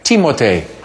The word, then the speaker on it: Timothée: If you really want to call this young actor by his name, pronounce it the French way, Timothée.